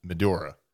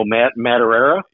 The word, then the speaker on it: Madureira: but Joe Madureira. Did I say that right? – Joe Madureira? – Yeah, that's